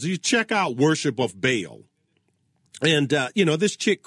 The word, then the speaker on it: Baal: They worship Baal, or Baal, B-A-A-L. In English, We pronounce it Baal. It's kind of hard to do that that Baal thing with that guttural throat sound. That's a little bit tricky.